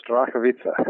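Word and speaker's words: Starachowice: Strakowice, is that correct? – Very close, Strakowice. – Okay, Strakowice. And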